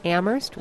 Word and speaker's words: Amherst: Not exactly a huge crowd, but in a town where a popular t-shirt reads Amherst, where only the H is silent, lengthy debate is a given, especially on an issue like the Guantanamo Bay prison.